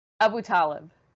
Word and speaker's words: Abutaleb: Yasmeen, we are out of time, but I got to ask you this one last quick question because I think I'm putting the emphasis on the wrong syllable. Your last name, Abutaleb or Abutaleb? – Abutaleb. – Ah, I see. I knew I mispronounced it the first time.